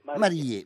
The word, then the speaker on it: Marie: Come si pronuncia, Filippo Farinelli? – Marie. – Marie, grazie.